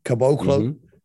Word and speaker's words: Caboclo: Caboclo, is that how I pronounce it? Mm-hmm. Caboclo.